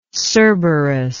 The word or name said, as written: cerberic